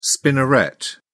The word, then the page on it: spinneret: British English